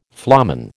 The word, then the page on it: flamen: American English